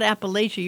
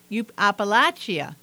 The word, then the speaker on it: Appalachia: You pronounce it not Appalachia. You pronounce it you don't say Appalachia. – Appalachia. I've learned something new today. – Yeah, like latch on a door.